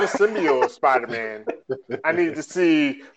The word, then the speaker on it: symbiote: Oh, symbiote. – Symbiote. Did I say that right? Symbiote. – Symbiote. Yes.